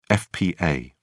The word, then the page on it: fpa: British English